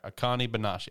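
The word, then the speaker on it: Akane-banashi: Uh, and then Akinani. Okay, you you pronounce it. How is this thing pronounced? – So how I would say it would be Akani. Akani. Akani Manashi. – Akani Banashi. Okay, Akani Banashi.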